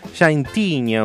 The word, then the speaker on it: Santiña: Ella es conocida en Río Paranaíba como Llantiña. ¿Lo dije bien? No, es muy bueno mi, portugués. Santiña, una.